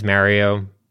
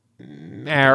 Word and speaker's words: Mario: Try saying it with a sort of a long A or a short A. – Mario. – I was confused. – Mario. – Ah. Say the word off. Say off. – Off. – Okay, now take that ah sound and put it in Mario.